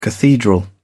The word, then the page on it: cathedral: British English